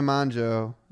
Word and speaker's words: Mongeau: Tana Mongeau, is that how you pronounce her last name? Mm-hmm.